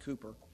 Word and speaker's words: Cowper: William Cooper, his name is actually spelled C-O-W-P-E-R, looks like Cowper, but they pronounced it Cooper, was an English hymn writer, and he put it this way, God moves in mysterious ways, his wonders to perform.